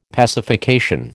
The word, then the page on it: pacification: American English